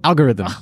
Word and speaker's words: algorithm: Am I saying the G too hard? – It's more of a soft G? Al-Jor? – Algorithm. – Al-Jor-a-thyme? Al-Jor-a-thyme?